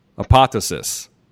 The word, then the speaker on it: Apotheosis: So, but I want everybody out there, guys, Apotheosis, new record by Allegaeon.